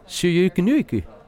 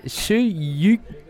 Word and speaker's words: Söyüncü: Çağlar Söyüncü. Nee, Söyüncü. – Voor mij Söyüncü.